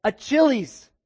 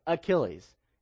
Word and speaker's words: Achilles: And when he went to read it, he said, Achilles, and lost because you have to pronounce the right way. So the next girl got it right, Achilles.